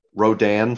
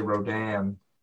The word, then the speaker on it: Rodan: You say it like Roseanne. – It is Roseanne. – Yeah, but I call him Rodan. And you call him Rodan. You have a different emphasis on the Dan.